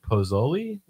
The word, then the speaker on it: pozole: Mary said a smoked pork pozole.